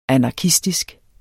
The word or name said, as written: anarkistisk